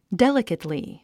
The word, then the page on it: delicately: délikətli; デリケトリィ